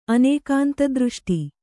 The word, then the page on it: ಅನೇಕಾಂತದೃಷ್ಟಿ: Kannada